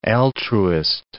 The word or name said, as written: altruist